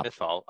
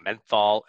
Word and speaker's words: Mythal: Mythal. I was saying his name wrong earlier in the review. I was like saying Menthol and Mythosol.